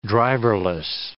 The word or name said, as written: driverless